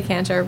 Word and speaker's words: Kantar: I mean, I say Kantar, but I don't really care.